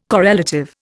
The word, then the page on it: correlative: Indian